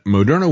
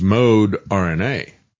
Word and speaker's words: Moderna: And Moderna was all about mRNA. As a matter of fact, the way they initially pronounced their name was Mode RNA. And then they changed the pronunciation to Moderna.